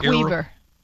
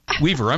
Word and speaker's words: Weaver: And is it pronounced Nick Weaver? – Weaver.